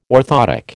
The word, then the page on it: orthoptic: American English